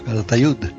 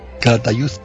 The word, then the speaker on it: Calatayud: Pero que muchos madrileños lo pronunciarían Calatayud. – Con Z, pues lo mismo que también hay muchos que te dicen Madrid.